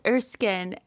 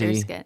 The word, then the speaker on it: Erskine: It's Erskine. It's. I feel like people just like to, they feel like it should be more complicated, but fancy. It's just Erskine.